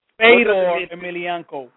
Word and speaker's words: Fedor Emelianenko: Fila, I may be saying his name wrong. Fila Emiliano. And, of course, Muhammad Ali. – Fedor Emelianenko. Sorry.